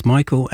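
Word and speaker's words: Mykaell: It's M-Y-K-A-E-L-L, and it's pronounced Michael, and it's the result of numerology.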